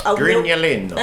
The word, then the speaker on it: Grignolino: É Grignolino? Porque Grignolino. – Eu acertei a pronúncia.